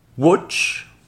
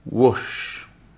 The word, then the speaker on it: wash: Vous avez watch, qui veut dire regarder, to watch, par exemple I watch TV, et vous avez wash, qui veut dire ici laver. Wash. je répète les deux. Watch, wash vous avez la différence de prononciation entre fit,